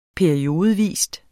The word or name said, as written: periodevis